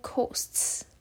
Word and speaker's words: costs: 那在 cost 后面，它其实是 ts 的音啊，我们不会念 costs，因为 ts 的音只要碰在一起，我们就会念成 ts ts。注音的那个词，就是永远哦，它都是这发这个音，所以我们就会说 at all costs，连音的感觉再放一次给你听哦。